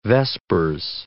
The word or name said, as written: vespers